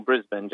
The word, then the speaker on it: Brisbane: They pronounce it Brisbane or Melbourne. It's just Melbourne or Brisbane. Just imagine the end of the bin.